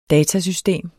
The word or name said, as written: datasystem